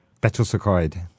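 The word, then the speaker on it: Betws-y-Coed: I can't really pronounce this, but I think it's called Betty's Ecoet. – Betws y Coed.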